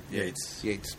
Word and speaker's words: Yeats: Yeats? The fuck do you say his name? – Yeats. – Perfect.